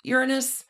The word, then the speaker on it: Uranus: Now, Cronus gets to be king of the Titans because he overthrows, Cronus does, his father, Uranus. – Are you sure about the pronunciation of that name? – Yes, I am. – Okay. – One little fun thing Cronus does is he castrates Uranus and throws his castration materials into the ocean.